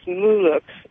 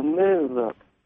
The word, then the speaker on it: Muluk: And it's Muluk, actually, it's pronounced Mu with accent on the first syllable, and then look. So Muluk.